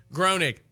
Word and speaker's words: Groening: Groening, yeah.